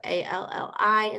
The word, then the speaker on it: Alli: So Alli instead of Ally.